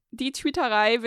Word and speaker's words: Die Twitterei: I'm called Die Twitterei, which unfortunately is German. So it's spelled like die. I'm sorry, but die. It's the German article.